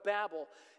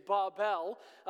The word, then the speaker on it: Babel: Uh, the actual correct pronunciation in the Hebrew is Babel, um, but to make it easy on the ears, um, I will stay with with Babel as that's how we would pronounce it in our English language. Um, and if you're from England uh, or the UK area, it would be Babel. Uh, but we're just going to stick with Babel here, just just to make it easy as we walk through this.